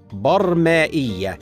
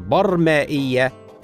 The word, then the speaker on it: برمائية: من انواع المخلوقات الحيوانات البرمائيه هكذا تنطق بفتح الباء والميم وسكون الراء ولكن البعض ينطقها بغير ذلك وهذا خطأ شائع والصواب نطقها هكذا برمائية ولذلك نقول حيوانات برمائية